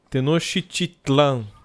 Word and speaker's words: Tenochtitlan: Tenochtitlan, capital do Império Azteca.